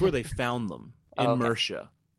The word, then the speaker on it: Mierce: Mirsha is spelled M-I-E-R-C-E.